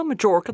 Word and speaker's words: Majorca: Yeah, Majorca, that's how you pronounce it.